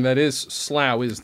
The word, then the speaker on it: Slough: They call themselves Sloughs, S-L-O-U-G-H. – After Slough, where Heathrow Airport is. – Oh, that's how you pronounce that? – It is. Yeah.